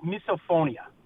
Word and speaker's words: misophonia: I believe it's pronounced misophonia. M-I-S-O, misophonia, misophonia, something like that.